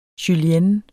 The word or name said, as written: julienne